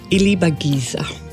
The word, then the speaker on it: Ilibagiza: Today I have the pleasure of talking to Immaculée Ilibagiza.